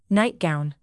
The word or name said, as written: nightgown